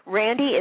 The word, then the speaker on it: Randi: Randy is spelled R-A-N-D-I.